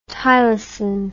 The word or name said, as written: tylosin